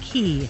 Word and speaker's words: Cay: And it's pronounced Key. – K-A-C-A-Y? – Oh, C-A-Y? – I know how to pronounce key. – I thought, okay. – Why? – Well, there's a thing. – Half Moon K, C-A-Y. – Okay, I thought it was the Q-U-A-Y thing.